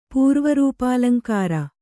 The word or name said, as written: ಪೂರ್ವರೂಪಾಲಂಕಾರ